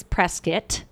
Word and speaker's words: Prescott: One is Prescott, even though it's spelled Prescott.